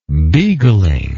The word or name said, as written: beagling